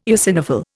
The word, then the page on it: eosinophil: Indian